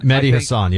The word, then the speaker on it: Mehdi Hasan: Hasan, how do you pronounce his name? Mehdi Hasan? – Mehdi Hasan, yep.